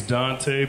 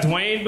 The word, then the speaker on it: Duante: Yeah, my name's Dante, but it's spelled all fucked up, though. Yeah, it's D-U-A-N-T-E. I get everything from Duante to Duant. I've even got Dwayne before, like the T silent and shit.